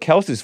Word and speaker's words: Kelce: It's actually pronounced Kelce, K-E-L-S-S, and not Kel hyphen C-S-E-E. No, it's Kelce.